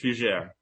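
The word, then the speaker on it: Fugère: My last name is French, Fugere.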